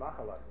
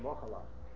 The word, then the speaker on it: Mochala: It says in the Mincha Shai, the correct pronunciation is Mochala, not a Pata, and not a Shvo under the head. Mochla, it should be Mochala.